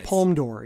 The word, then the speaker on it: Palme d'Or: Yeah, the Palme d'Or, yes, that's correct. – The Palme d'Or, I think particularly when you and I were first getting into movies, the Palme d'Or seemed to signify, like, Sex, Lies, and Videotape won the Palme d'Or.